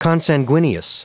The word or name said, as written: consanguineous